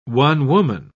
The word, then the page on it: one-woman: British English